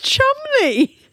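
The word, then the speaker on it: Cholmondeley: You said it's pronounced Chumley. – It looks like Chumondley. – Slightly French. – It looks like it's like Chumondley or something, doesn't it? But it's Chumley. Your speech.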